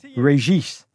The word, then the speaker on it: Regis: He actually pronounces it Regis. And you're going to hear a lot of people butcher his surname on this podcast, but it's Regis, as he's become best remembered in US soccer history.